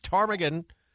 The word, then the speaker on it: ptarmigan: Yeah, I noticed the list starts not with turkeys or rabbits, but with ptarmigan. Did I say that right? Ptarmigan? – Yes.